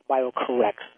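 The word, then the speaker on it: BioCorRx: B-I-O, bio, and it's C-O-R-R-X, and pronounced BioCorrex, like you're correct.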